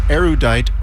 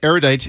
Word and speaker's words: erudite: It's pronounced erudite or erudite. That's where I get the different pronunciation. Either is acceptable. Erudite is not, dear A. So erudite is the way I like to pronounce it.